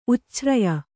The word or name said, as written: ಉಚ್ಛ್ರಯ